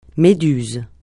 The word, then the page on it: méduse: francais